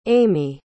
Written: ami

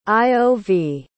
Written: iov

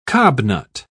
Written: cobnut

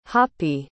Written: hoppy

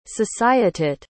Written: societeit

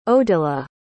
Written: odila

